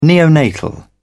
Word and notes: neonatal: British English

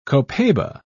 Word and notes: copaiba: British English

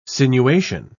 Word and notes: sinuation: British English

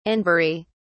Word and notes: anbury: British English

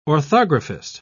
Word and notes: orthographist: British English

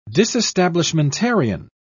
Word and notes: disestablishmentarian: British English

